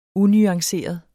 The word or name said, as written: unuanceret